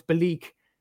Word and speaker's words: Belleek: Um, I, I I know how to pronounce this, Aaron. I know this for a fact. No, it's Belleek. That's how you pronounce their name.